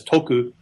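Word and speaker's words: toku (徳): And I have to assume there was something similar in this period, so that in Japanese, the character for virtue, which is pronounced in Japanese as toku, is a homonym for a character which means profit, which also is read toku, though it's a different ideogram.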